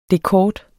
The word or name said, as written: dekort